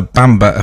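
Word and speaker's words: Mpemba: This phenomenon is known as the Bamba effect, and it's spelled M-P-E-M-B-A. I had to check how to pronounce it.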